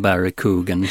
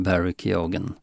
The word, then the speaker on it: Keoghan: Men sen hittade jag en intervju i tv med Barry Keoghan där vi då får veta att man faktiskt uttalar G-et också.